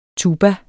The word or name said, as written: tuba